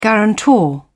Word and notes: guarantor: British English

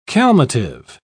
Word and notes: calmative: British English

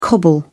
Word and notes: cobble: British English

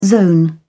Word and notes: zone: British English

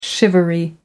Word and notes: shivery: American English

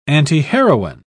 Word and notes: antiheroine: British English

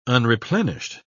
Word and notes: unreplenished: British English

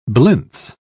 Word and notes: blintze: British English

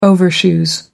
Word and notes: overshoes: American English

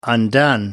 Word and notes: undone: British English

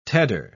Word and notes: tedder: British English

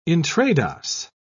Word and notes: intrados: British English

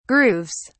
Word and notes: grooves: British English

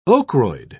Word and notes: ochroid: British English

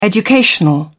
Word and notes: educational: British English

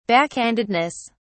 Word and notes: backhandedness: British English

